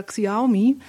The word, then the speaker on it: Xiaomi: Ja, es geht ja das Gerücht rum, dass am Mittwoch eventuell der chinesische Hersteller Xiaomi ein Event halten wird.